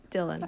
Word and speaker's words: Dylan: Dylan. – Okay. – Dylan, okay. – Okay. – So we have two songs. They of course are not Dylan songs, but at least this way you get some taste of how these two singers sang.